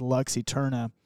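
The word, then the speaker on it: Lux Æterna: That song is called Lux Eterna. I think that's how you're technically supposed to pronounce it. He says Lux Eterna.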